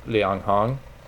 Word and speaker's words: Liang Hong: A lot of the writers, my pronunciation of Chinese is not great, but it's Zha Pinghua, no relation to the director, Yu Hua, and Liang Hong. – First and third are good.